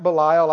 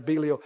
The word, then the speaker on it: Belial: Some people pronounce it Belial, I know, but the ones I've read it after, Belial.